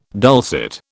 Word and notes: dulcet: British English

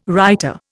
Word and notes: writer: Indian

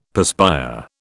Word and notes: perspire: British English

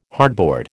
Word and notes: hardboard: American English

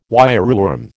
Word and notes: wireworm: American English

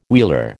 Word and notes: wheeler: American English

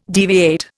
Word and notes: deviate: Indian

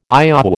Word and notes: IOW: American English

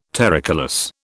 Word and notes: terricolous: British English

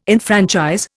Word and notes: enfranchise: Indian

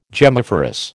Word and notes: gemmiferous: American English

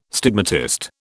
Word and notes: stigmatist: British English

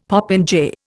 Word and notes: popinjay: Indian

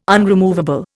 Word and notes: unremovable: Indian

